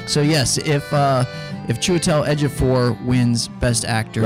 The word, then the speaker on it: Chiwetel Ejiofor: Well, if Cheetawal Ejiofor wins. – What? A Cheetawal to die to for? To die for a proper pronunciation. It's Chiwetel Ejiofor.